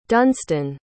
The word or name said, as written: dunstan